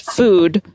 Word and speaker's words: Fude: I believe it's just probably pronounced food. Strength food, but it's spelled food is spelled F-U-D-E.